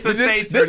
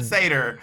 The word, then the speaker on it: satyr: It's a satyr, not satyr. You said this satyr.